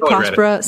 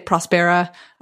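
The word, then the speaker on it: Próspera: Yeah, I pronounce it Prospera. Some people say Prospera. I've heard different things from even the team at Prospera. – Okay, so but the accent is on the O. So like if, you know, and it's a Spanish speaking country. So I think it's Prospera.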